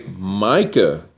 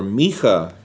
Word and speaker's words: Micha: Then it goes on to say, Micah or Micha, the way you pronounce it in Ibrit,